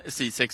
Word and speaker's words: Sisek: Christian Sisek tror jag att man uttalar det, som ska recensera maten på de allsvenska arenorna. Farsa, du kan väl börja med att rätta mitt uttala efternamnet där ifall det inte var korrekt. – Nej, men det var helt korrekt.